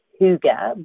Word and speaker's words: hygge: And hygge, and it's pronounced hygge, but it's H-Y-G-G-E.